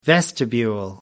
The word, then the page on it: vestibule: American English